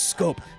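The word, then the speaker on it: Schoop: Number 33, Jonathan Scope. Sounds like Shoop, spelled like Shoop, but it is Scope.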